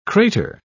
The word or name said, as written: crater